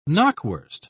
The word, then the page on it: knockwurst: British English